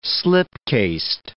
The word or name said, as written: slipcased